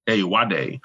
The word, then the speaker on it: Ayoade: Ayoade.